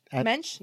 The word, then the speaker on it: mensch: was a real mensch move on his part, end quote. I don't know if I pronounced that word correctly. Mensch? – Mensch? – Mensch, yep. – I think that's how you say it.